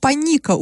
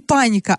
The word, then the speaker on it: Паника: Оренбургский поселок Паника участвует в конкурсе на самое смешное название. Ударение в этом названии падает на второй слог, то есть не паника, а паника.